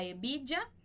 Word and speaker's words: hebilla: Então, esse H do A não vai ser pronunciado, nem o H de hebilla.